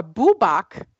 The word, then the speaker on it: Bwbach: Well, you know how it's spelled? B-W-B-A-C-H. Or Bubak.